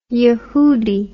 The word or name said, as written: Yahudi